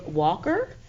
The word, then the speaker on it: Walker: Again, my name is Lene Van Hook Walker, and that's spelled L-I-N-E-E and then W-A-L-K-E-R, Lene Van Hook Walker.